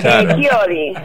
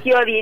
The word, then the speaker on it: Chiodi: Chiodi, sí, Chiodi en italiano. Chiodo un clavo, Chiodi es como que somos varios.